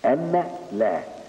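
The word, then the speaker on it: أن لا: لا، تكتب بالنون، اشهد ان لا اله الا الله، تكتب النون ولا تنطق ان لا، النون تكتب لكنها ايه؟ لا تنطق.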